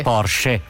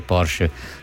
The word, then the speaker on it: Porsche: Anche Porsche, perché effettivamente sono nomi tedeschi e quindi andrebbero pronunciati così, però certo dire Porsche sembra di voler fare i fenomeni, diciamo la verità.